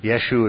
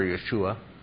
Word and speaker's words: Yeshu: Yeshu would be the Jewish pronunciation, Yeshu or Yeshua.